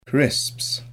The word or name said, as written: crisps